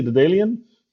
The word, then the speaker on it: Daedalean: Hey, you're the CEO of Datalin. Did I say it right? – Almost. I say the Dalian, but I accept any.